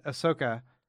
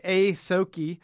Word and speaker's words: Ahsoka: Every time I say it, I think I say it wrong. – You say it differently, but that was right. – Ahsoka and... anyway, Ahsoki. – Ahsoka.